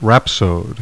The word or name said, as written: rhapsode